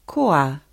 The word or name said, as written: koa